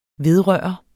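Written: VEDRØRTES

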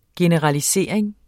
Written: generalisering

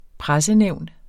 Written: pressenævn

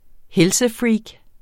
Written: helsefreak

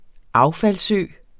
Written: affaldsø

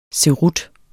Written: Cerutter

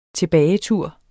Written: tilbagetur

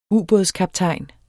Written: ubådskaptajn

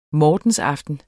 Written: mortensaften

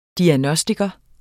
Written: diagnostiker

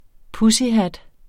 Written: pussyhat

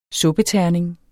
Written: suppeterning

